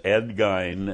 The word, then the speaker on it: Gein: So, I didn't realize that with Ed Gein, that he was a mama's boy like that. – Oh, yeah, he was. And I should say it's Gein. – Ed Gein. – That's how it's pronounced, Ed Gein.